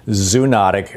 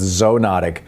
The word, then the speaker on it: zoonotic: And also, help me with my pronunciation. This is one of those words that I don't think I've ever heard. I've always just read. I always pronounced it in my head, zoonotic. But somebody called yesterday and said it's zoonotic. Tell me about these diseases. – I'm not sure if I can help the pronunciation debate. I think it might be tomato, tomato. But I can tell you a little bit about zoonotic diseases. That's how I pronounce it.